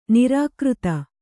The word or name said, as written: ನಿರಾಕೃತ